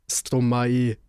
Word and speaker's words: Stromae: Nein, das stimmt wiederum nicht, das kann ich sicher sagen, weil es kommt ja von Maestro, Stromae, der die Silben ausgibt. – Ja eben, aber es ist nicht Stromae so abgehakt, es ist so ein fließendes Ei.